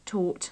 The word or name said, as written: taught